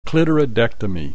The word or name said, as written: clitoridectomy